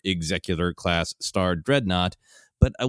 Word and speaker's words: Executor: I remember being yelled at, I believe, by my brother that it should be pronounced Executor.